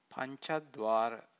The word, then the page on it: pancadvara: Pali